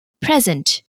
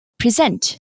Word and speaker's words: present: For example, present, where the stress is usually on the first syllable, or verbs, for example, present, where the stress is on the second syllable.